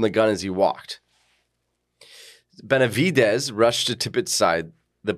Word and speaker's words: Benavides: Domingo Benavides.